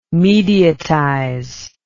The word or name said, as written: mediatize